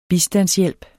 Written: bistandshjælp

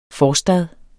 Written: forstad

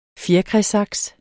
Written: fjerkræsaks

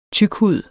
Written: TYKHUDER

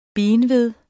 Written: benved

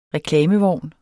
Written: reklamevogn

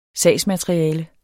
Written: sagsmateriale

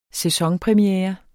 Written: sæsonpremiere

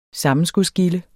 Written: sammenskudsgilde

